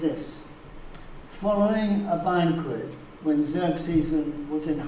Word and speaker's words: Xerxes: I think it's Xerxes, spelled X-E-R-X-E-S, strange word.